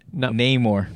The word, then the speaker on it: Namor: Namor. – Namor. – I know. Literally just remember Namor. – I'm going to write it down. How about this? – Do the nay more.